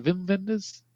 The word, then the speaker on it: Wim Wenders: Is that how you say it? Wim Wenders? It's German, so I just always pronounce it W's as V's.